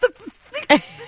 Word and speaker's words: tzatziki: Because you have a T and a Z and an S all right after each other. – Yes, and. – It's tzatziki. – Am I saying it right? – Tzatziki, guys. It's tzatziki.